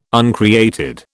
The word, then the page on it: uncreated: British English